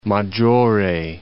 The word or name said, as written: Maggiore, Lake